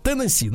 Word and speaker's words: Теннесси: Теннесси, надо так делать ударение ударение.